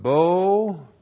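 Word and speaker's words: Bo (בוא): Looks like that in the Hebrew, Aleph, A-S-H-E-R, Asher. Asher, you translate that relative pronoun who, and then the verb bo. B, long O, Aleph. Bo, that's the verb to come, who have come, and then mi babel, preposition min, prefix to Babel.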